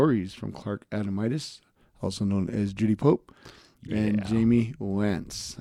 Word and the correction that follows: Wanzik: I think, did I say that right? – Wance. Jamie Wanzik. – Wanzik, there you go.